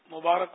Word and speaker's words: مبارک: اس کا تلفظ مبارک را پر زبر آئے گا, را کے نیچے زیر غلط. مبارک عام طور پہ لوگ بولتے ہیں عید مبارک, تو عید مبارک نہیں, عید مبارک.